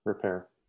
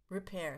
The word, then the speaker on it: Ripert: is it Ripert or Ripert? – Repair. – Repair, excuse me. Well, of course it's French, at Le Bernardin.